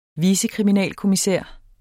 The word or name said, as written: vicekriminalkommissær